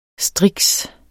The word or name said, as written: striks